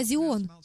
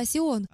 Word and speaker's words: Сион: Гора Сион, которую некоторые называют гора Зион, но правильно говорить гора Сион, Гора Сион в еврейских писаниях и во всей Библии употребляется как синоним всего Израиля, всего Иерусалима.